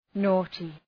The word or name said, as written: naughty